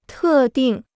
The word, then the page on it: 特定: tè dìng